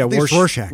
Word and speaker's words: Rorschach: Familiar with the, I think they're pronounced Rorschach tests, I think there are, the blot, the ink blot. – Yeah, yeah. – How do you say it? – Rorschach? – It starts with a W. Yeah, Rorschach. – Rorschach.